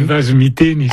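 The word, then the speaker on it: vazo miteny: corrigez ma prononciation, des Vasomiteni, comment on dit? – Des Vasomiteni, ça veut dire...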